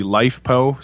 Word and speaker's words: LiFePO4: So basically, LifePO, some people call them, mm-hmm. LiFePO4.